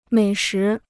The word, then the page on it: 美食: měi shí